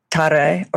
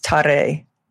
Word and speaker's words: Tara: Her name Tare, spelled T-A-R-A in the English alliteration, but often pronounced Tare.